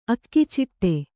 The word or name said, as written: ಅಗಚಾಟು